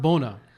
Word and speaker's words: Buna: It's pronounced bona. – Oh, okay. – Yeah. – I was making fun of Rasam yesterday because that's what he was saying. – Yeah. – I was like, bro, what are you saying? – He makes fun of me like that, yeah. But okay. – So it's more like Bonafide. That was the name of my first album. – Oh, okay. – Bonafide, Bon Appetit.